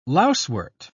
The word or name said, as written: lousewort